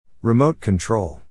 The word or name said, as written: remote control